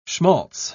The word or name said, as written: schmalz